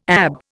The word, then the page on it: ab: Indian